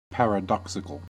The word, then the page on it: paradoxical: American English